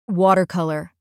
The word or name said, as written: watercolour